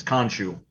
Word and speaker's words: Khonshu: I think I'm pronouncing that right. It's Khonshu. – Sounds perfect.